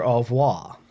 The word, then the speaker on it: au revoir: It's the Adam Sandler au revoir. – Au revoir. – Au revoir? – Au revoir. – Au revoir. You kind of skip the R.